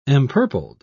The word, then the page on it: empurpled: British English